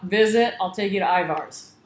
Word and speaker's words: Ivar's: Ivar's, that's where, is that how you say it? Ivar's? – Ivar's.